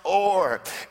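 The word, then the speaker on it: or (אור): It is the word pronounced or, and it literally means to shine like the break of day.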